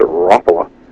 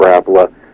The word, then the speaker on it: Rapala: And really, it's come from, you have a bit of their accent in it, and they almost pronounce it Rapala. – Mm-hmm. – But it's, you know, anyways, we call it Rapala. That's actually the way it's supposed to be pronounced.